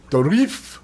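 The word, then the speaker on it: Dourif: Dorif. I did call him up, and he said that's how it's pronounced.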